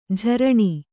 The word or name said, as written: ಝರಣಿ